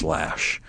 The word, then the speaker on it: Loesch: By the way, just so you know, she spells her name L-O-E-S-C-H, but it's pronounced Lash.